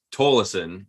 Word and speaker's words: Tolleson: Because it's spelled, Tolleson is how we say it in the family. And I've heard that and many other variations before. – Yeah, because it's spelled Tolleson, like yes.